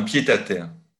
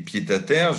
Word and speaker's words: pied-à-terre: Je fais la liaison avec le pied, je ne dis pas un pied à terre, je dis un pied à terre.